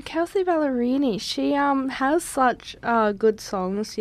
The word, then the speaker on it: Kelsey Ballerini: Cliche? – Oh, Kelsey Ballerini.